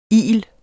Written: il

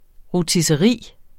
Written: rotisseri

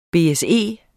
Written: BSE

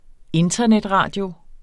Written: internetradio

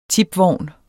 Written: TIPVOGNE

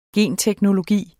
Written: genteknologi

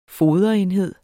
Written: foderenhed

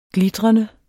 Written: glitrende

